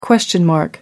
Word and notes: question mark: American English

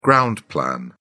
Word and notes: ground plan: British English

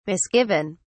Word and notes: misgiven: British English

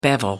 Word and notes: bevel: American English

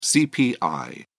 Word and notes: cpi: British English

